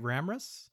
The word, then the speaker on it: Ramras: Ramras? – Mm-hmm.